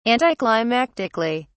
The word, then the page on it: anticlimactically: British English